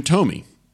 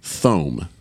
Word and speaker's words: Thome: This is from Jim Tomey, or as you might phonetically pronounce his last name, Thome, as he was on MLB Tonight.